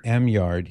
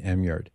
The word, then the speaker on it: Eymard: Emyard. His last name is spelled E-Y-M-A-R-D. Could be pronounced, maybe it's better pronounced, Amard.